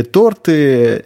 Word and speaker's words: торты: Торты или торты. – Э, торты.